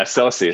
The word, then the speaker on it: Sosie: Yeah, Sosie.